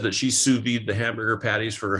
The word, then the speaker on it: sous vide: S-I-U-S, two words, V-I-D-E. – Oh, okay. So the second S is silent now. It's called sous vide. – Sous vide. Yes. And it's French. And so, Not Suba or Subaru, but sous vide.